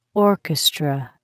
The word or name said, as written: orchestra